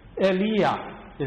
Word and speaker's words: Eliyah: Eliyah is how it is pronounced.